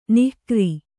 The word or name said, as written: ನಿಕಾರಿ